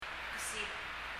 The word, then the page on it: kesib: [kɛsib]